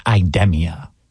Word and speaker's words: Idemia: I got in contact with Lucy Shoemaker, the Senior Vice President of Corporate Relations at Idemia, okay, which is the proper way to pronounce it.